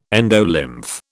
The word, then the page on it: endolymph: British English